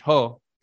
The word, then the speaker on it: JOH: There was a so-called president named Juan Orlando Hernandez, who's known by his initials J-O-H, which you pronounce in Spanish, Ho.